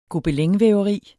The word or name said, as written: gobelinvæveri